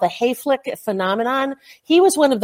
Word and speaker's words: Hayflick: And if your listeners really want a really, really interesting discussion about it, it's Dr. Hayflick, and it's spelled just like it sounds, Hayflick, H-A-Y-F-L-I-C-K.